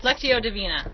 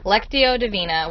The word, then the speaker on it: Lectio Divina: Lectio Divina. – Lectio Divina, which is, it's like a more of a meditative way of reading scripture.